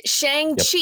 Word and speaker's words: Shang-Chi: Shang-Chi. Yep. Yes, I said that right. Shang-Chi and the Legend of the Ten Rings will be coming to Disney Plus mid-October.